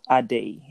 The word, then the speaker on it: Ade: But yeah, my name is Ade, by the way. – All right, pronounce it like adding. – No, Ade, A-D-E.